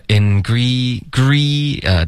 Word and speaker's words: Ingredients: Ingredients, 的 E 的音舌根要高一点 okay? Ingredients.